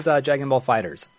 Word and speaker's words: Dragon Ball FighterZ: Like, Yeah, and then apparently they came out and be like, yeah, it is Dragon Ball FighterZ. That's how you correctly pronounce a game.